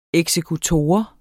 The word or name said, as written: eksekutor